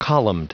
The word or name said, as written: columned